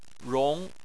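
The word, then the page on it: rong: tone 2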